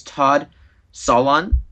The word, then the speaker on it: Solondz: I always pronounce his name wrong, but it's Todd Solondz.